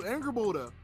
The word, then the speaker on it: Angrboda: Oh God, I can't pronounce her name. I'm not good with when it comes to those freaking Norse names from the new God of War, the black girl. It's like Angrboda. I don't know how to pronounce it. Angarboda. No, you did. I, you did a wonderful job, Angrboda. You're right on the mark.